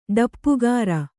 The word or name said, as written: ಡಪ್ಪುಗಾರ